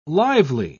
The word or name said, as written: lively